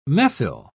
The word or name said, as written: methyl